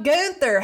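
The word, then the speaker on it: Günther: So the whole weekend, he was like, so Gunther, how do you feel about this? And it was like, Gunther the whole time. Gunther, yeah. Gunther, Gunther. – Hard emphasis on the gun. – Gun, gun, yeah.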